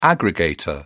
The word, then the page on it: aggregator: British English